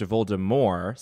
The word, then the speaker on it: Voldemort: Rowling abides by this, it's pronounced Voldemort, silent T. – What? – And then the guy who read the audiobooks pronounced it Voldemort